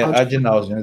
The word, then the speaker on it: ad nauseam: ad nauseam, é assim que se fala? Eu falei certo? – É, ad nauseam, exatamente.